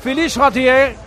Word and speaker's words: Philippe Chatrier: Philippe Chatrier, o como lo dijo consejero, Ángel García.